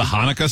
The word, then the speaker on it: Hanukkah: Like, how do you say it? – The Hanukkah song? – Oh, is that what it is?